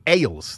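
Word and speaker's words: Egils: But uh, they do have local breweries, including Ales. That's E G I L S. I believe it's how it's pronounced as Ales.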